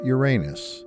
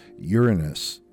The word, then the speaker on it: Uranus: When it comes to the sky, most people call the seventh planet Uranus. But this is never correct, because Roman and Greek gods have single correct pronunciations. It's Uranus, even though Alex Trebek gets this wrong on Jeopardy.